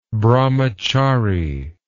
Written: brahmachari

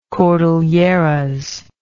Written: cordilleran

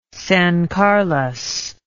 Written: San Carlos

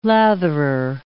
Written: latherer

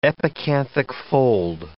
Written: epicanthic fold

